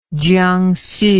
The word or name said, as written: Jiangsu